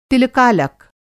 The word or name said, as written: तिलकालक